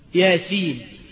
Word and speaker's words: يس: هي مكتوبه كده، مكتوبه ايه؟ يس. ولا تنطق هكذا بل تنطق باسم الحرف ياسين، مش كده ولا ايه؟